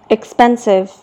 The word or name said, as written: expensive